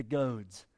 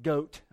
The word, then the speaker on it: goad: It is hard for you to kick against the goads. And that's not goat, okay? I didn't misspell that. It's actually goad.